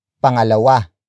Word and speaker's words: pangalawa: The second exception is pangalawa.